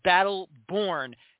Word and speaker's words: Brattleboro: It is Brattleboro FC.